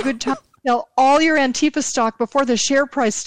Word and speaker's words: Antifa: He said Rand Paul has called to subpoena how do you say it? Do you say Antifa? – Antifa.